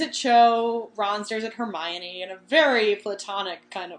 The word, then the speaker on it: Hermione: And she says, Hermione. – Yes. – And then I'm pretty sure the whole world rejoiced because we finally know how to say Herman's name. – Yes. Because I think Krum never gets it. He says Hermonini.